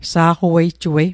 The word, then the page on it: sa'qawejjuei: Mi'gmaq